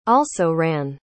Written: also-ran

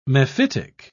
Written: mephitic

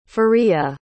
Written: fariha

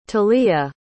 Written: thalia

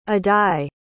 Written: addai

